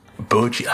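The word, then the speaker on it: Bojji: No timetable for the release, but Boji's Boji, I call him Boji. That's the way I say his name.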